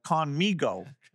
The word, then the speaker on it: Khanmigo: the Conmigo, if I'm saying that right. – Yeah, that's right. Very good.